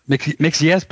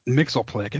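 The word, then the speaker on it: Mxyzptlk: Mixless, I think is how it's pronounced. – Mr. M for me. – If it's not Gilbert Gottfried, it's not a character. – Martin says it Mixleplick, and that's not right.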